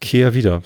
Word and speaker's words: Kehrwieder: Kehrwieder, so wie K-E-H-R. Also nicht Care Englisch, sondern Care. – K-E-H-R? – Wieder.